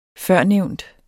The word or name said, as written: førnævnt